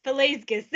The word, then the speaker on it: Pelasgus: Pelagus. Pelasgus. There, we're just going to say Pelasgus.